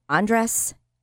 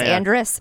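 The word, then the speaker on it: Andress: It was the Major League Baseball game, and Ingrid Andres. I think I pronounced it that way in the beginning, but it's not. I churched it up. Andres.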